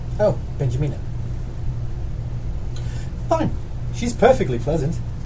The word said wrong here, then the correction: Benyamina: Is that how you pronounce it? – It's Benyamina.